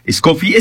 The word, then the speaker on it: Escoffi: Estamos hablando con Carla Escoffi. ¿Es Escoffi? ¿Cómo pronuncio tu apellido? Perdón. – Escoffi, pero como gustes pronunciarlo, porque lo conocían de mil por más.